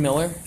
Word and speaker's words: Miller: My name is Jaden Miller. J-A-D-E-N. M-I-L-L-E-R.